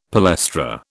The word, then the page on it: palestra: British English